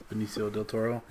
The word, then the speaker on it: Benicio del Toro: Benicio del Toro, yeah.